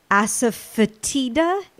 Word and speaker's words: asafoetida: like asafoetida. Is that proper pronunciation? – Yes.